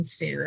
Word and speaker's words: Hsu: You can just look for my name, Dr. Helen Hsu. That's HSU, so it's silent H.